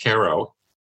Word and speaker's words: Cairo: This town is written as if it's Cairo, Illinois, but we pronounce it Cairo, Illinois. And Cairo, Illinois is at the confluence of the Mississippi and Ohio rivers.